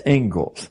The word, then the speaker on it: Inglis: Looks like Inglis, but it's pronounced Ingalls.